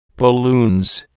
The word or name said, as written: balloons